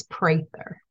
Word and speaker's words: Prather: And I just want to say, because my husband may watch this, it's Prather.